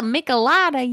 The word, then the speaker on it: Michelada: I guess I don't have to like pronounce it so like, you know, Anglo. which I did. It's a Michelada, y'all.